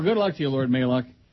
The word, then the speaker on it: Maluk: Have a great show, Neil God, respectively Lord Maluk, and he tells me how to pronounce it. M-A-L-U-K, Maluk.